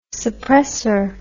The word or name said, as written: suppressor